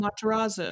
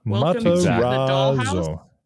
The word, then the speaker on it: Matarazzo: Her name is Heather Matarazzo. – Matarazzo. – And I'm sure that's how you say it too. – Matarazzo.